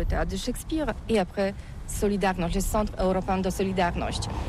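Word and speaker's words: Solidarność: Alors solidarité, en français on dit solidarnosc, mais ici à Gdansk on ne prononce pas solidarnosc.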